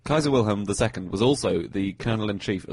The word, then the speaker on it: Kaiser Wilhelm: So Kaiser Wilhelm, as you correctly pronounce it, Kaiser Wilhelm II was Kaiser of Germany, was King of Germany until 1918.